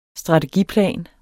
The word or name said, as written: STRATEGIPLAN